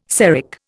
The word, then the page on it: Seric: Indian